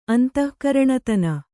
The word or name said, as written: ಅಂತಃಕರಣತನ